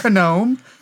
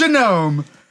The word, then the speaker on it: genome: Oh, gnome. Oh, yes. Not gnome. It's genome. That's right.